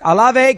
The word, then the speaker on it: Olave: Olive's of uh, Olave, uh, uh Olave, whatever. It's the kid from Ohio State. Uh, Olave, Olave, Olave. That's it. Olave, Chris Olave.